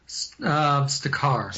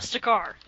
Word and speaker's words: Stakar: and was ostracized by is it Stakor? How do you say that? – Stakar. – Stakar, that's it.